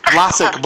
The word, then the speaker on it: Blasick: Am I pronouncing that right, Blasick? – Blasick.